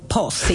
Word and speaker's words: Paws: Y lo último, según un estudio realizado por Honest Post. Así se pronuncia, ¿no? ¿Está bien? – Post, sí, perfecto. – P-A-W-S, por si acaso.